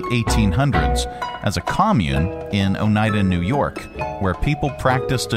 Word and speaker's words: Oneida: It's Oneida. – Oneida. It's Oneida? – Oneida.